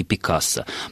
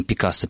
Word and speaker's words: Пикассо: То есть, если со словом «Пикассо» – «Пикассо» возможны варианты, и все-таки словари регистрируют как предпочтительную форму вот эту вот уже устоявшуюся «Пикассо» на французский манер, то Рембрандта мы все-таки, наверное, должны называть Рембрандтом.